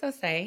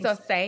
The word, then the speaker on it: Osei: Is it Osei or Osei? – Okay, so it's Osei. – Osei. – It's Osei because it's West African.